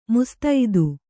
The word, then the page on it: ಮುಸ್ತೈದೆ: mustaide